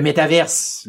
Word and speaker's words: métavers: Le métaverse, le métavers, euh, les deux prononciations en français sont euh, acceptées généralement. Est-ce